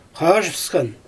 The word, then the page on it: xaaʒɯfsxan: Xyzyl